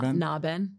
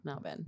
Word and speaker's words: Nyangban: It's not Naben. – Naben? – Naben. – No G. Okay, got it. – It's a silent G.